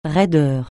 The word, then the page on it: raideur: francais